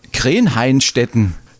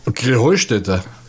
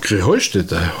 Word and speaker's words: Krähenheustätte: Ja, ihr saget immer Krenheinstetten. Und dabei heißt es doch Krähenheustätte, oder wie es? – Krähenheustätte. – Ja, genau, und das ist die Betonung auf dem Heu. – Krähenheustätte, Heustätte, ja.